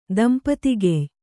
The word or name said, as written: ದಂಪತಿಗೆಯ್